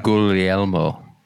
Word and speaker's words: Guglielmo: Is that a soft G in there? The Gug? Is it Guglielmo? – You don't pronounce actually the second G. Okay. It's mute. – So yeah. Guglielmo Schiavoni.